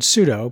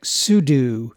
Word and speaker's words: sudo: Most people pronounce it like the word pseudo, P-S-E-U-D-O. Back in the Unix days, us oldies pronounced it like sudo. The name actually stands for switch users and do, which is probably why we pronounce it that way.